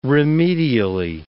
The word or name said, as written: remedially